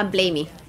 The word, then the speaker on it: Blamey: Ah, Blamey.